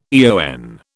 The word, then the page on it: eon: British English